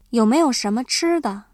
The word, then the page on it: 吃的: chīde